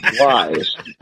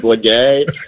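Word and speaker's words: Blige: I believe it's pronounced Wise. But once upon a time, one of the three of us, I don't know, I can't remember which one of us, might have actually pronounced her last name Blagay or something like that.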